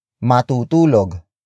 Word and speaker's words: matutulog: Matutulog. Matutulog. Matutulog is spelled as M-A-T-U-T-U-L-O-G.